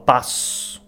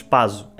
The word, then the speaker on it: passo: Mas, passo, pronunciamos igual, passo. Não pronunciamos paso.